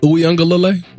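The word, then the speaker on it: Uiagalelei: Did you put an extra syllable on that? – Uyunglele? – No, that's good. That's good. – Uyunglele. – Uyunglele. You're good. You're good there.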